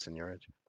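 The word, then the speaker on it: seigniorage: But as far as the future of Tomb and Signorage, you know, in general, I don't even know, is that how you say it, Signorage? – Yeah, I think it's right.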